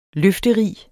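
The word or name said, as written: LØFTERIG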